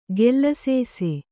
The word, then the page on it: ಗೆಲ್ಲಸೇಸೆ: gelasēse